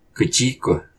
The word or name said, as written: Quciq